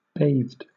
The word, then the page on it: bathed: British English